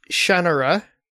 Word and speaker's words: Shannara: I pronounced it wrong. Is it Shannara? – Okay, it's supposed to be pronounced Shannara. – Shannara. – Apparently. Oh, okay. And I acknowledge that that is the official correct pronunciation of it.